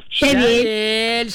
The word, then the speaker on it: щавель: Независимо от склонения или числа, ударение всегда ставится только на окончание. Так что правильно говорите еще раз. Как, дочка? – Шавель.